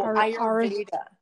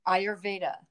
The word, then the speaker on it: Ayurveda: So you have to tell me how to pronounce this wonderful word. – Oh, Ayurveda. – Ayurveda? – Ayurveda. – Mm-hmm. Ayurveda. Okay, perfect.